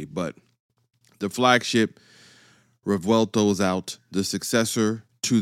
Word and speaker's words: Revuelto: The Revuelto. Uh-huh. Yeah, you heard that. Real Italian. Spelled R-E-V-U-E-L-T-O, Revuelto. That is now the successor to the Aventador.